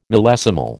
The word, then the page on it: millesimal: American English